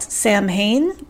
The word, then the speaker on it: Samhain: It's spelled Samhain, but it's pronounced Samhain.